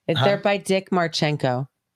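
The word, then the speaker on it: Marcinko: It's not, it's spelled weird. It's M-A-R-C-I-N-K-O. N-K-O. Yeah. Marchenko.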